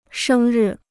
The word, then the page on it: 生日: shēng rì